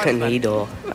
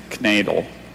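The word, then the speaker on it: knaidel: Okay, that was canadal. Are there any alternate pronunciations? – There's just canadal and knadel. – Knadel. – Canadal. K N A I. – He looks for Clint. – D-E-L, Canadal.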